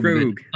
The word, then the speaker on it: Krog: But but it, it's Krog. It rhymes with Vogue or Rogue. – Krog.